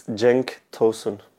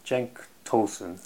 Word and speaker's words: Cenk Tosun: It's Cenk Tosun. – Cenk Tosun? – Yeah.